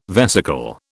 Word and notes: vesicle: British English

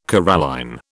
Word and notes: coralline: British English

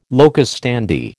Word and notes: locus standi: American English

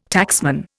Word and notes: taxman: Indian

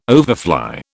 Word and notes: overfly: British English

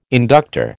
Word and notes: inductor: American English

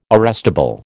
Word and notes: arrestable: American English

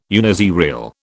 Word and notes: uniserial: British English

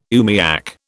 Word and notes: oomiak: British English

